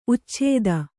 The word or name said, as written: ಉಚ್ಛಿತ್ತಿ